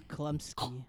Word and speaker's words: Chlumsky: I don't how to say her last name? Chlumsky? – No, it's Klumsky.